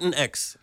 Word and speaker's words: Latinx: Latinx. It's a new term that we can talk about, yeah. – Okay, Latinx, LGBT programming. Glitter Bomb is an explosive pop culture talk show hosted by an all-gay Latinx Hollywood insider panel featuring Patrick Gomez, Enrique Sapene.